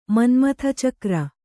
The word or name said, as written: ಮನ್ಮಥಚಕ್ರ